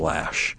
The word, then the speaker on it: Loesch: By the way, just so you know, she spells her name L-O-E-S-C-H, but it's pronounced Lash.